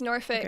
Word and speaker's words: Norfolk: Robert was born in Norfolk, and I'm sure I'm saying this wrong. Did I say it right? – No, it's right. It's Norfolk.